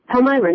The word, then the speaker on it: Palmyra: Palmyra, New York, by the way. – Palmyra. – That's how you pronounce it.